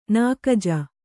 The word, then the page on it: ನಾಕಜ: Kannada